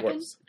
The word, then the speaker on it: Schwartz: How do you pronounce that? – Schwartz. Brecken. – Schwartz. And then, so is there a little, like, an unspoken T? Or yeah, Schwartz. – There's a voice T, but it's not actually written in there.